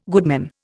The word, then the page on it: goodman: Indian